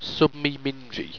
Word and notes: submemingy: owstwecian

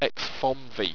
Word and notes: exfommvy: Owstwecian

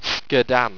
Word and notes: sqedan: owstwecian

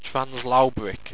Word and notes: translaubric: owstwecian

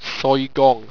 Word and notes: soygong: owstwecian

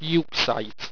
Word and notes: euxite: Owstwecian

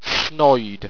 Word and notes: snoide: owstwecian

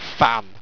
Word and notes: ffan: Owstwecian

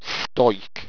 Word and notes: stoiq: owstwecian

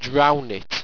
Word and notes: drounet: Owstwecian

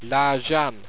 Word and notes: laajan: Owstwecian

